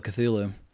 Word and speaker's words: Cthulhu: so it's like even Cthulhu, but it's supposed to be like two syllables, like Hulu, but he designed them in a way where they're not supposed to be able to be pronounced by us.